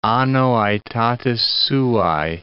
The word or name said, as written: anno aetatis suae